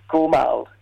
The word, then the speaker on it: Gurrumul: It is called Gormal, and that's spelled Gurrumul.